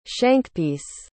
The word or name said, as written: shankpiece